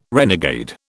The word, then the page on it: renegade: British English